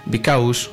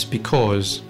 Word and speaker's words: because: And the pronunciation is precisely that, because. But some Spanish speakers tend to say bicaus or bicous, okay? There's no diphthong there, it's because or because, okay?